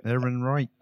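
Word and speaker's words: Ehrenreich: Aaron Reich.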